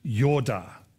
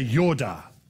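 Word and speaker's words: yoda (ידע): It's actually pronounced in the original Hebrew, yoda. Everybody say yoda, right?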